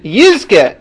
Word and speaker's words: Yizke: So he points out, number one, the real proper way to say that should have been, Yizke, if you now will mourn Yerushalayim properly, Yizke, in the future, you will see the Nachama of Yerushalayim when it is rebuilt.